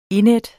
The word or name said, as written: indædt